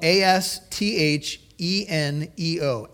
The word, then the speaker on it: astheneo: It is spelled A S T H E N E O, astheneo.